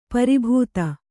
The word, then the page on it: ಪರಿಭೇದ: pari bhēda